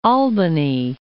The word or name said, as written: Albany